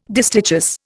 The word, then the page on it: distichous: Indian